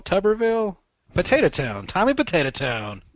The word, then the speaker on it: Tuberville: I think it's Tuberville, right? Is that how you pronounce his name, Tuberville? – That's right.